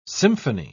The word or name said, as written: symphony